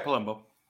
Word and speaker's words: Palumbo: I say that right, Palumbo? Yeah, Palumbo.